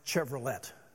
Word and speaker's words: Chevrolet: Years ago, much older man, even than I am now, corrected his father and said, Dad, the company is pronounced Chevrolet.